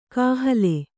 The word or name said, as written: ಕಾಹಲೆ